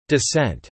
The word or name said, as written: descent